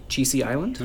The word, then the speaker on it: Chisi Island: but Chisi Island.